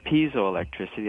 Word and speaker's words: piezoelectricity: And then we have piezoelectricity, that's spelled P-I-E, like pie, P-I-E-Z-O, electric, E-L-E-C-T-R-I-C.